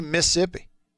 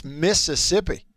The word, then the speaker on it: Mississippi: Don't say Mississippi. It's Mississippi. Look, if we wanted people to say Mississippi, we wouldn't spell it with two sets of double S's. – M-I, crooked letter I, crooked letter I. – Right. – Humpback I. – It's spelled the way it's spelled, therefore you say it the way you say it.